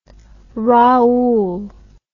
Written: raoult

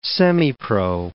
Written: semipro

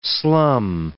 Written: slum

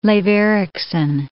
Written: Leif Eriksson

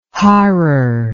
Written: harrar

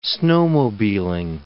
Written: snowmobiling

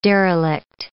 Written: derelict

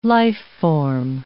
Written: life-form

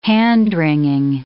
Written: hand-wringing

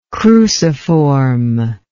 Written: cruciformly

